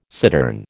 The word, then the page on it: cittern: American English